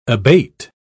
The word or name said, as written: abate